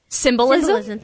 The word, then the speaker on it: symbolism: And I've never, don't know what the symbolicism is. I didn't say that right. – Symbolism, thank you.